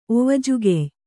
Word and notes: ಒವಜುಗೆಯ್: ovajugey

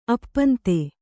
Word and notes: ಅಪ್ಪಂತೆ: appante

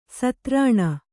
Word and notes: ಸ್ತ್ರೈಣ: straiṇa

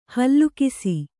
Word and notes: ಹೇಲುಕಸ: hēlu kasa

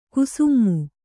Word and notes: ಕುಸುಮೆ: Kannada

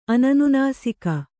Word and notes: ಅನನುನಾಸಿಕ: ananunāsika

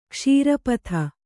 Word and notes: ಕ್ಷೀರಪಥ: kṣīra patha

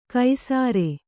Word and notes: ಕೈಸಾರೆ: kai sāre

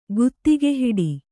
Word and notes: ಗುತ್ತಿಗೆಹಿಡಿ: guttigehiḍi

